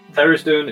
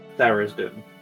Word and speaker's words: Tharizdun: Also, Thera's Doom is spelled THARIZDUN. – I thought it was two words, to be honest. I was 100 miles away. – Thera's Doom.